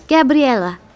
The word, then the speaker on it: Gabriella: My name's Gabriella.